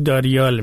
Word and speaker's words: داریال: و این در الان، الان به روسی داریال میگن تلفظ خودشون، این